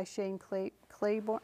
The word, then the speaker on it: Claiborne: I just got done reading the book Rethinking Life by Shane Claiborne. I never get his name right. Shane Claiborne? – Yes, correct.